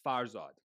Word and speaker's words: Farzad: I know Farzad is a it's actually pronounced Farzad, but I don't even pronounce it that way, because I'm like just used to hearing my name back from everybody. So I'm like, Farzad, that's my name.